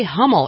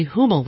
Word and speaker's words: Hummel: If you say it incorrectly, if you say Hummel, you're calling the guy a goat in German. It's actually Hummel, which means butterfly.